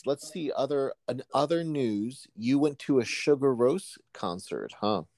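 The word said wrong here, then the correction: Sigur Rós: Zigur Ross is is that how you say it? – Yeah, yes, a Scandinavian guy.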